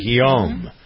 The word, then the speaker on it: Guillaume: Guillaume.